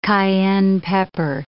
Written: cayenne pepper

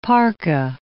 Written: parka

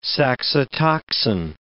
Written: saxitoxin

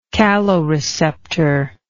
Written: caloreceptor

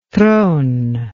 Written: trone

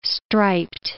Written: striped

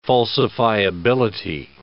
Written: falsifiability

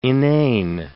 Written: inane

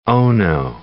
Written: ono

